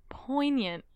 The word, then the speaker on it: poignant: The G is silent. – Mm-hmm. – Poignant.